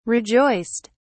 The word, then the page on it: rejoiced: British English